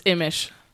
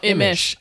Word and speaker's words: Imesch: It's Imesch. – Imesch. – Yeah. – Imesch. – No, Imesch.